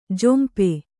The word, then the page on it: ಜಂಪು: jampu